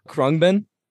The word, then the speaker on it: Khruangbin: Okay, so what I've been listening to, I've been listening to uh, actually, I can't even say it properly. Karumbagan. Oh, Khruangbin? Khruangbin, yeah.